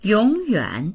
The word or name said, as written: yong3yuan3